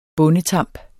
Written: bondetamp

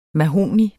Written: MAHOGNI

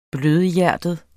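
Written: BLØDHJERTET

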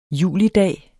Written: julidag

